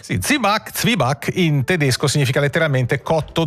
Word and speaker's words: Zwieback: Si scrive Zwieback, scritto con la V doppia e tutto il resto, ma si sente spesso dire Zibac, scritto proprio così anche. – Zibac. – Molti lo scrivono così, Zibac. Z-I-B-A con l'accento C. – Esatto, la pronuncia si semplifica, si ticinesizza, perde un po' di spigoli tedeschi e diventa Zibac, come hai detto tu.